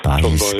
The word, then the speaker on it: Baez: Sagt mir der Joan Baez oder Baez? – Baez, gell, ja. – So. – Latinisch ausgesprochen.